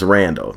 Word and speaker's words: Randall: The name is Randall.